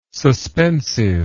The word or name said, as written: suspensive